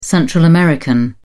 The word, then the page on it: Central American: British English